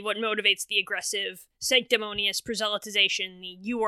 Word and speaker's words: proselytization: shut up alien. proselytization i said it correctly